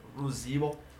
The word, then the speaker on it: ruzivo: Knowledge is Ruzivo. – Ruzivo. – Knowledge. Ruzivo. – Ruzivo. – Yes. So like your VH, it's a B.